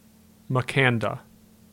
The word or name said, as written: Makanda